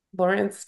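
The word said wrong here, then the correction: Larenz: Did I say his name right? – Lorenz Tate.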